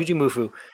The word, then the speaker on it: Jujimufu: especially for somebody that doesn't go on social media anymore but so a short on YouTube of I think his name is Jujimufu. – Yeah. – Yeah. Is that how you say it? Okay. – Yep. Jujimufu in inline rollerblades.